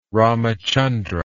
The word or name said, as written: ramachandra